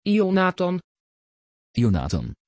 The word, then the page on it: Yonathan: Dutch